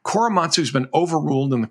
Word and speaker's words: Korematsu: Korematsu, is that my pronouncing that? – I mentioned Korematsu, which was never actually overturned.